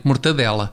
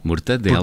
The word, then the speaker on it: mortadela: corretamente mortadela. Muito bem. Já Mor é bom. Mortadela. Porque muita gente diz mortandela.